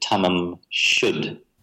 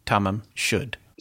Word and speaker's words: tamam shud: I've spoken to students from Iran to find out how to correctly pronounce this because it's a Persian word. They told me you should pronounce it tamam shud.